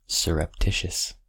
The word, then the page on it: surreptitious: American English